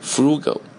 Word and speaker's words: frugal: Frugal, 第一个单词 Frugal, 跟着我念啊， Frugal, F-R-U-G-A-L, 拼写自己来。那么这个单词呢，你怎么来记啊？我们把它拉长一点发音 Frugal, 腐乳吃过吧？腐乳大家吃过吧，就是喝粥的时候或者吃泡饭的时候给你一块腐乳，对吧？